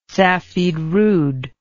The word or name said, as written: safidrud